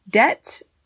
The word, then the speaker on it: debt: Debt has a silent B, D-E-B-T, and it is something that you owe, usually money.